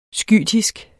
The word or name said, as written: skytisk